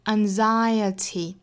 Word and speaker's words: anxiety: 那最后一个词 anxiety，我们要注意这个词首先重读音节呢是在第二个音节上，